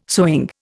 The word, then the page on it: sewing: Indian